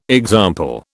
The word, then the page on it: example: British English